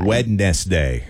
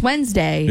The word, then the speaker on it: Wednesday: W-E-D-N-E-S-D-A-Y. – Wednesday. – Which, that's not how we say, it's not, we don't pronounce the D. – No. But it's there. – It's Wednesday.